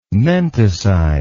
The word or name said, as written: menticide